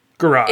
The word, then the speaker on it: garrote: I can't pronounce this word right. Garrote? – Garrote. – It's garrote. Thank you.